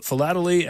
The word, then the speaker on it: philately: Dan Piazza is chief curator of philately at the Smithsonian National Postal Museum. Dan, in my business, it's dangerous to mispronounce words. Did I say that right? Philately? – You did. You got it right.